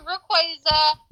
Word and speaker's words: Rayquaza: How to pronounce Rayquaza. Fuck. – Oh! Got him! – Rayquaza, suck my nuts. – I told you, Rayquaza.